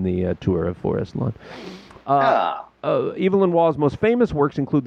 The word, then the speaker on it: Evelyn: The first name is pronounced with a long E, as in Eve. Evelyn. It is generally pronounced like this when it's a man's name.